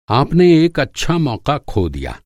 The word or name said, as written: मौक़ा